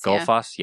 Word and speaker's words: Gullfoss: Gullfoss, yeah.